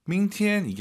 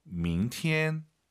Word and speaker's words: 明天: 이성 출발점이 낮게 가야 돼요. 明天 이게 아니라 明天.